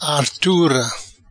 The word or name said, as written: Artura